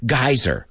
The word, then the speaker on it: geyser: Well, I just got the British pronunciation because we call it a geyser.